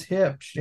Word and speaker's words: Hipsch: I'm sitting here trying to pronounce this to Laundry Concepts up in Chicago and they're like, oh, it's Hipsch.